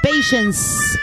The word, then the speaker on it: patience: Patience. Con S al final, patience.